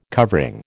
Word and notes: covering: American English

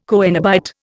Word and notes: coenobite: Indian

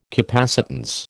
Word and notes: capacitance: American English

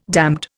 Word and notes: damned: Indian

